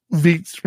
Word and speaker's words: Vite: Vite rhymes with feet.